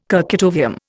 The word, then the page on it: kurchatovium: Indian